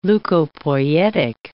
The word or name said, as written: leukopoietic